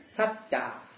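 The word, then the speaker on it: sattā: and that word is satta, S-A-T-T-A, long A. The second A is long A. Now, the word S-A-T-T-A with short A means a being, like bodhisattva, right? Satta. So, isatta means a being. But satta, with the long, second A long, means the state of being, or the state of being present, being existent, being present.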